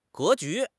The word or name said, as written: 格局